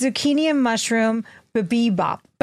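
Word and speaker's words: bibimbap: I'm going to show all our YouTubers the bimbap. – You don't say that correctly. – That, excuse me. Babimbap.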